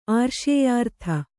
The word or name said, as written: ಆರ್ಷೇಯಾರ್ಥ